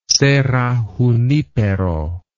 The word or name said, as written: Serra Junipero